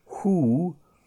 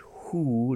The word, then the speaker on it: who: But in the question word, who, we are pronouncing and stressing the H. Who, not who, yeah? Okay, so W-H-O is how the word is spelt, but the stress and the pronunciation is who, like the owl. Who, yeah? W-H-O.